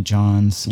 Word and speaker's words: John: John also has a silent H, and there's something about these silent letters that don't do it for me.